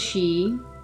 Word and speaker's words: Sidhe: Some of the Xi, this word, if you're not familiar with it, is spelled S-I-D-H-E. Some of the Xi